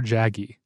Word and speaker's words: Jaeggy: called These Possible Lives by a woman named Fleur Jaggi. I think that's how you pronounce it. It's, her last name is J-A-E-G-G-Y.